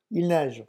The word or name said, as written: il neige